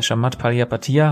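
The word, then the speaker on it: Chamath Palihapitiya: Shamat Palihapatiya, habe ich es richtig gesagt? – Mhm, ist äh, richtig, ja.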